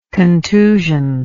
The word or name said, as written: contusioned